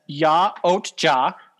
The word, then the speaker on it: Yautja: Predators are actually known as Ya-Ot-Ja. That is the real name of the predators. – Yeah. – And so Y-A-U-T-J-A.